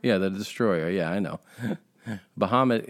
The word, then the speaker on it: Bahamut: First of all, I didn't realize this until a couple days ago, but Bahamut is how you pronounce it in Arabic, is from mythology.